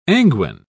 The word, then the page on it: anguine: British English